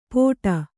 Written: ಪೋಟ

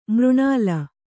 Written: ಮರನೆಲ್ಲಿ

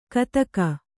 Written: ಕತಕ

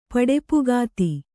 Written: ಪಡೆಪುಗಾತಿ

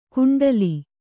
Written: ಕುಂಟಲಿ

